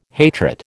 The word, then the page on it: hatred: American English